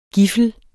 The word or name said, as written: giffel